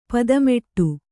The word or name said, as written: ಪದ್ಮಾಟ